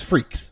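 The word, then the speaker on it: Freecss: It's Freaks.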